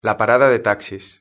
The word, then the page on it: la parada de taxis: European Spanish